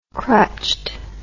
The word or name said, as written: crutched